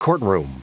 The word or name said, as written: courtroom